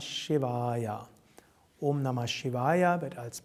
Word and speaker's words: Shivaya: Und dann Shivaya. Das I ist kurz, das A ist lang und das Ja ist kurz.